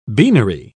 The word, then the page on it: beanery: British English